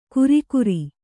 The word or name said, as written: ಕುರಿಕುರಿ